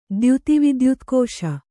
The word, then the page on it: ದ್ಯುತಿವಿದ್ಯುತ್ಕೋಶ: dyuti vidyutkōśa